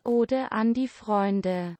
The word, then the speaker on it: Ode an die Freunde: The German, because it's in German, is pronounced Ode an die Freunde. – There you go.